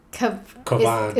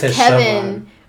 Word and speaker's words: Kavan: Kavan. – Is it Kavan? – Yeah. – Or I thought it was Kevin. But no, spelled funny. It was Kavan because yeah.